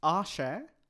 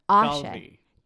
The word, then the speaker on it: Aashay: The pronunciation is incorrect. – Oh, I'm so sorry. Tell me. – It's Aashay. Aashay. Dalvi.